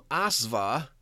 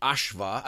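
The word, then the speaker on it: aśva: While it may become Asva under, or actually Ashva, I think the Sanskrit retain, as does German, and I think the Hebrew, the S is actually pronounced like an SH.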